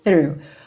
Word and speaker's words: through: E essa palavra through, não entre em pânico quando você vir THROUGH. Não, through, ok? A pronúncia dela é bastante simples, mas tem que começar com a linguinha lá na pontinha dos dentes da frente, ó. Linguinha para fora da boca mesmo.